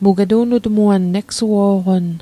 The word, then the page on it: egsuo'qon: Mi'gmaq